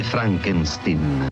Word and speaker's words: Frankenstein: Si legge Frankenstein. – Con Gene Wilder,